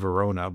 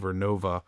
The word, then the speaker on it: Vernova: And if you look at stocks, like I always say the name wrong, it's like I want to say GE Verona, but I know it's GE Vernova, and a stock called Vertiv.